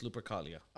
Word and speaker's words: Lupercalia: If it's pronounced as base level, it's Lupercalia.